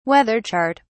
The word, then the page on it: weather chart: British English